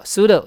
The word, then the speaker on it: pseudo: 看得出来吧，两个音节叫 pseudo， P 不发音哦。你们听过江南大叔叫 Psy 对不对？他绝对不是念 Psy，好，或者是变成吉普赛人吉普赛，太难听了。所以这个字念 pseudo， pseudo 你翻字典， pseudo name 就是假名，假的化名，像这个就是假名化名艺名。好， pseudo 在英文的话 P 不发音，我们再往后面走。